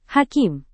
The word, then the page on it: hakim: Indonesian